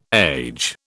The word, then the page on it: age: British English